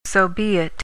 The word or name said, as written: sobeit